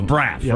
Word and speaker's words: Braff: Yeah, I think Just Braff, right? B-R-A-F-F.